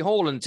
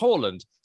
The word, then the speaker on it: Haaland: And he said this: I remember Alfie Haaland telling me I was getting his name wrong in Leeds commentary. It's pronounced Haaland.